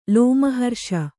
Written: ಲೋಮಹರ್ಷ

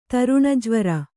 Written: ತರುಣಜ್ವರ

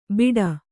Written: ಬೀಡಾ

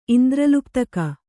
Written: ಇಂದ್ರಲುಪ್ತಕ

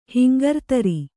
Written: ಹಿಂಗರ್ತರಿ

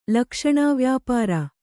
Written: ಲಕ್ಷಣಾವ್ಯಾಪಾರ

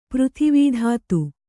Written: ಪೃಥಿವೀಧಾತು

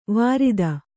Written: ವಾರಿತ

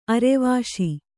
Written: ಅರ್ವಿಸು

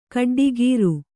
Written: ಗೊಡ್ಡಕಾರ

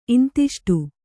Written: ಇಂತಿಷ್ಟು

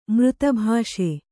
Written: ಮೃತಭಾಷೆ

